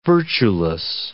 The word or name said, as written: virtueless